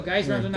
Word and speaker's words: Geis: Geis rhymes with nice.